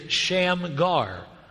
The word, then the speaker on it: Shamgar: After him was Shagmar, or Shamgar. I'm saying that wrong. It's written wrong. It is Shamgar.